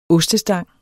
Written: ostestang

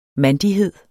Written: mandighed

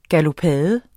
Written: galopade

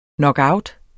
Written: knockout

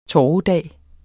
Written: torvedag

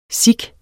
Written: sikh

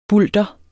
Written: bulter

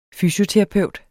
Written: fysioterapeut

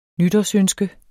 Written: nytårsønske

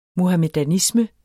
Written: muhamedanisme

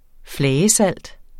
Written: flagesalt

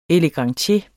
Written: elegantier

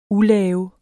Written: ulave

